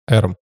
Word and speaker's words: eram: Эрм. Должно быть ударение на первый слог в этом слове.